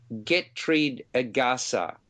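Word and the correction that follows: Getreidegasse: Gaza simply means a narrow street. So Getreide Gasse, that's probably better.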